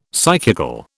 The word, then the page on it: psychical: British English